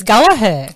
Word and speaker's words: Gallaher: It is G-A-L-L-A-H-E-R. Most people think there should be a second G, so it's Gallagher. But, it's not.